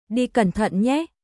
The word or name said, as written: Đi cẩn thận nhé